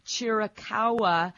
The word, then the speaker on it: Chiricahua: Chiricahua, I sure hope I pronounced it correctly. – Yes, you did.